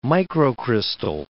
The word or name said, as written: microcrystal